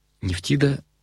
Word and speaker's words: Нефтида: Нефтида — это греческое произношение египетской богини Небетхет или Нептот, владычицы дома, и она была спутницей бога Ра, который отождествлялся с Амоном, была защитницей мертвых.